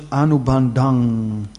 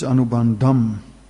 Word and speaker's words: anubandham: Like if you look at the first word, that is if in the verse it's pronounced as anubandang, like bon. But in the word for it is anubandham.